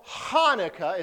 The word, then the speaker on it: Hanukkah: And remember, when you see this, it's got the CH, and that is their Flemish sound, right? Which we don't do, right? So Hanukkah is how you say it. We drop the C because we talk normal, okay?